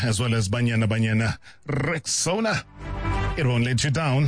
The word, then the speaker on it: Rexona: It's pronounced Rexona, simple, strong, and easy to remember.